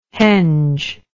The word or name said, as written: henge